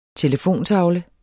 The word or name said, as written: telefontavle